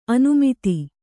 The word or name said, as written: ಅನಾಮತ್ತು